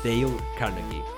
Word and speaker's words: Dale Carnegie: Sí, lo he buscado y se pronuncia Dale Carnegie.